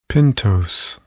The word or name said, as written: pentose